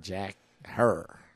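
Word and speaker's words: Herer: It rhymes with terror, my man. So just replace you go hair, the T not her, with an H hair. And Jack Hair.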